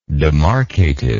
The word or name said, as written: demarcative